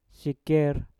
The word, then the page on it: siker: mpur